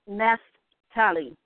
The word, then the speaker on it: Naphtali: But Adar is a month, and the tribe is Naphtali. It's Naphtali.